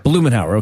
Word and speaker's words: Blumenauer: So Blumenauer.